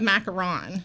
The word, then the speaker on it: macaron: It's a macaron.